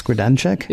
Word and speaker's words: Grodénchik: Is it Max Grodénchik? – Yeah, very good.